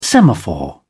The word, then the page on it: semaphore: British English